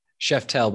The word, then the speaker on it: Szeftel: Szeftel, but pretty close.